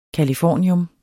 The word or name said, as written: californium